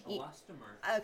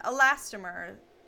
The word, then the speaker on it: elastomer: Menstrual cups are made from medical grade silicone, latex rubber, or I don't actually know how to say this, honey. – Elastomer. – Okay, that's what I thought. Elastomer.